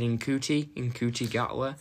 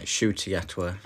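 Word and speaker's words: Ncuti Gatwa: I think I pronounced his name wrong first time I read it. – I think we did, I think we said Nkuti Gatwa. – Yeah, Shuti Gatwa.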